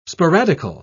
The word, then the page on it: sporadical: British English